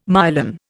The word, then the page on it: myelin: Indian